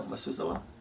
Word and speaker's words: mezuzah: You know, in the Jewish tradition, they have the mezuzah. Am I pronouncing it correctly? Yeah.